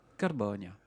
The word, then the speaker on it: Carbonia: Carbonia. – Je sais pas, je le fais à la Corse, moi, donc c'est plus difficile. – Oui, mais si, parce que c'est accentué sur l'antépénultième syllabe.